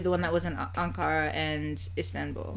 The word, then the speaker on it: Ankara: It's Ankara.